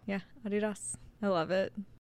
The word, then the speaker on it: Adidas: That's how we say it in our house is Adidas. – That's how it's technically pronounced. – Yeah. – Because his name was Adi.